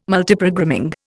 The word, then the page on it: Multiprogramming: Indian